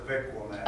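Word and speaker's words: Avi Kwa Ame: Yeah, it's pronounced different than what people want to say, Avicomay, but it's actually, the Mojave pronounces it as Avicomay.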